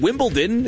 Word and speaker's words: Wimbledon: I always call this Wimbledon. Is it Wimbledon? Is that how you say it? I don't know why I put a T in there.